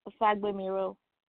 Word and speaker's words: Fagbemiro: It's okay, so I'm going to say the way Nigerians would, Fagbemiro.